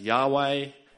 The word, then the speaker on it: Yahweh: Uh, so um, in Hebrew, it's pronounced Yahweh, and uh,